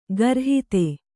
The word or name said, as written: ಖುರಾಹತಿ